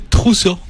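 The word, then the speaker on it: Trousseau: Sino que es esta variedad que obviamente acá nuestro colega doctor y amante de francés, ¿cómo se pronuncia Truso? – Monsieur Truso.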